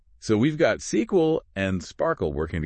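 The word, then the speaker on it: SPARQL: It relies on a powerful query language called SparkQL, and it's pronounced Sparkle.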